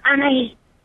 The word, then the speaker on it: Anais: My name is Anais. – Anais. Wrong emphasis on the wrong syllable.